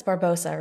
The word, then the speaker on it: Barbarossa: It's Barbarossa, right? – Barbarossa. – Barbarossa. What did I... yes, Barbarossa. – We have one more syllable in there.